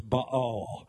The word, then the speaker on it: Baal: You know, I heard it called Baal all of my life, but the correct pronunciation of that Hebrew word is Baal.